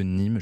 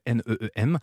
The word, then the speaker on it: Neem: Alors, pour les faire fuir, il y a une huile qui est très utilisée en Afrique qui s'appelle l'huile de Neem ou l'huile de Nîmes. Je sais jamais comment on la prononce. C'est Neem.